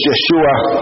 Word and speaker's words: Yeshua: And incidentally, the Hebrew pronunciation of Joshua is Yeshua, and we know it as Jesus, the Greek translation of it.